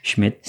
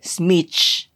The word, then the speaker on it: Schmich: I don't know how to pronounce that. – Schmidt? – Schmich.